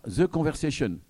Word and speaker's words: The Conversation: Et donc, Thomas Hofnung et Cléa représentent ce soir The Conversation. Je ne sais pas comment il faut prononcer, puisque c'est un journal français. – Oui, à l'anglaise, même si on est un média français.